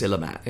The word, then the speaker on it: Illimat: And I say to people, it looks like Illuminati, but it's Illimat in terms of pronunciation and spelling and how you say it.